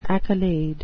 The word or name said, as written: accolade